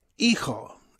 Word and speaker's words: hijo: Hijo, H-I-J-O. The H is not pronounced. The J is pronounced like an H.